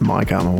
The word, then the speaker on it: Mikarma: I think I heard him pronounce it Mikarma once.